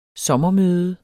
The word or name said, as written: sommermøde